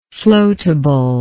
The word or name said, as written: floatability